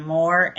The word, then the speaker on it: Moore: So it's spelled C-H-R-I-S-T-I-N-E-H, and then Moore, M-O-O-R-E, .com.